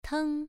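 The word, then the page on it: teng: tone 1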